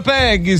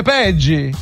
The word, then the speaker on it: Peggy: Sì, dissero, ma non si pronuncia Peggy, si dice Peggy.